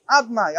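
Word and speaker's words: Adonai: So the Gemara says that now, the way Debish's name is written is not the way Debish's name is pronounced. It's written Yud Kei Vav Kei. How do we pronounce it? Adnai, Aleph Tav Nun Yud.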